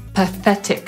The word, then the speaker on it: pathetic: Po brytyjsku wymówimy je jako pathetic, czyli zaakcentujemy drugą sylabę i tę zbitkę TH wymówimy jako czyli z językiem między zębami, tak jakbyśmy chcieli powiedzieć w tym układzie F, ale język między zębami musi być.